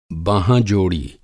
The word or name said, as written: बाँहाँ-जोड़ी